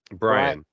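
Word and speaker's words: Bryon: Byron Russell. Boom. It's there. – Brian. – Brian. – Brian. – You said it again. – I did say it wrong again.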